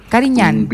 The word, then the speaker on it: Carignan: Oiga, eso va acompañado con un Carignan, ¿cómo se pronuncia? – Carignan.